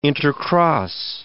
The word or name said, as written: intercross